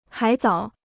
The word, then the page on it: 海藻: hǎi zǎo